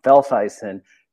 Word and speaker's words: Velthuysen: It is basically pronounced pretty phonetically, Velthuysen. You just get to throw in an accent there.